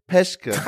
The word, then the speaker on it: Peschke: Und auf diesem dritten Stuhl nimmt jetzt Platz der André Preschke. Hallo André, ich habe deinen Nachnamen. Fast falsch ausgesprochen. Nochmal. André Peschke. Hallo.